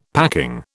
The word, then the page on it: packing: British English